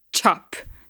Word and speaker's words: chop: E cuida com a pronúncia aqui, não é chop, é chop, tá? Quase como se tivesse um, um T ali. Ch, chop.